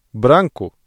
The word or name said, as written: branco